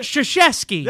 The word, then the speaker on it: Krzyzewski: Mike Krzyzewski.